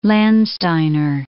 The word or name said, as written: Landsteiner